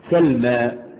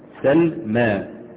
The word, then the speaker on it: سلمى: ألف التأنيث المقصورة، تكتب ياء، ترسم ياء وتنطق ألف، وقبلها في الكتابة يبقى مفتوح. يعني سلمى الميم عليها ايه؟ فتح. سلمى، ما، ما، قف على الميم كده، ما، سلمى.